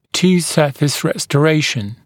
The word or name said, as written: two surface restoration